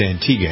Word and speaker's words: Antigua: I always used to pronounce it Antigua. – That's what I always said. Spelled that way. But she says it's Antigua.